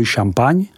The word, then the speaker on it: Champagne: Ten má ostatně geograficky blíže Champagne, vyslovuj Champagne, než k Burgundsku.